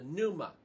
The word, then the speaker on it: pneuma: In chapter 17, now he changes Abraham's name and gives him the spirit, the breath, the pneuma. That's how it's said in Greek, all right?